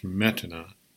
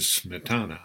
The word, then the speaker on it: Smetana: Here are several short pieces by Smetana, and it's not pronounced Smetana, it is Smetana, I looked it up.